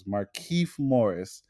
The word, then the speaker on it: Markieff: Markieff Morris.